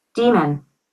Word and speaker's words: daemon: Demon. – Yeah. So DAEMON is pronounced like demon, right?